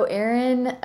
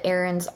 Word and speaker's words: Aeron: So Erin, you can get Erin's art at erinbrown.art. So it's spelled A-E-R-O-N. – Yeah. A-E-R-O-N, brown.art.